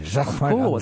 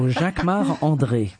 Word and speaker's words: Jacquemart-André: Jacquemart-André. You say it like André. – A Frenchman. – Jacquemart-André. Because I'm French. So Jacquemart-André. – And say it so we can understand it. – Jacquemart-André.